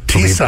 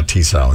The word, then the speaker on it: Tissot: Tissot. I believe that's it. Yeah, Tissot is how it's said.